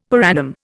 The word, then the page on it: per annum: Indian